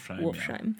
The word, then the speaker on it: Wolfsheim: Wolfsheim.